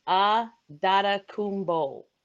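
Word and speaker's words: Antetokounmpo: So, and it's funny because the pronunciation guide for the Bucks says "Ah Dada Kumbo" is how they phonetically put it. And I'm like, oh, well, that makes sense. Adada Kumbo.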